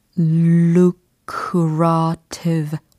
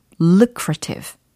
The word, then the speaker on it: lucrative: 발음은 과연 어떻게 될까요? look curative. 오, 접근 아주 훌륭하셨어요. 조금만 빨리 해볼까요? lucrative. 그쵸 lucrative가 아니고 l, l. 그쵸 l로 시작을 해야 되니까. 그 다음 lucrative, lucrative, lucrative 라고 연습해 두시면 되겠어요.